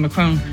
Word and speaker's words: Macron: And one quick note regarding Macron. And I know you're not supposed to pronounce the N, but I'm going for it.